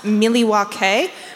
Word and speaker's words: Milwaukee: It's actually pronounced Miliwake, which is Algonquin for the good land.